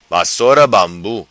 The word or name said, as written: vassora bambu